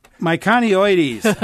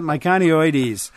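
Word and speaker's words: miconioides: Darlene, who says she has three Heptacodium myoconioides. Myconioides. I knew I'd mispronounced that. Myconioides.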